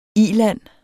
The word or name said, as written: ILANDE